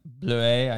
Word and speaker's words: Bluet: And it's from the winery Bluet. I think that's how you pronounce it. yeah, Bleu, I think is the, how the French would say it, but here in America, blew it.